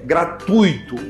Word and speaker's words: gratuito: Esse vocábulo não tem acento, pessoal. Cuidado, o lance é gratuito, gratuito. Fala a segunda sílaba junto, gratuito, gratuito, nunca gratuito. Isso configura um erro de fonética, de som.